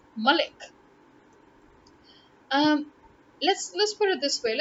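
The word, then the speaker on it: Malick: So it's Malik. Then why do you still say Malik? How does Lick translate to leak? That's Leak or Leek. Leek, right? This is lick. L-I-C-K, lick.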